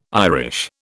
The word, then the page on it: Irish: British English